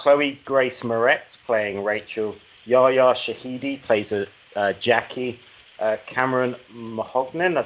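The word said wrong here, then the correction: Monaghan: Monahan playing Chad.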